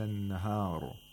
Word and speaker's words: النهار: If you stop at the word النهار, the ra would be مفخم. But if you don't stop, because the ra has a kasra, you have to pronounce the ra with ترقيق.